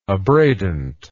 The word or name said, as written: abradant